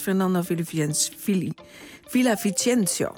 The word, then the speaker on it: Fernando Villavicencio: Presidentskandidaat Fernando Villavicencio, zo moet ik hem uitspreken, is vlak na een campagnebijeenkomst in de hoofdstad Quito doodgeschoten.